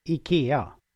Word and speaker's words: IKEA: How are we meant to pronounce this? We don't know. – Okay. I think I start with the first one or the second one, because that is easy. It's IKEA.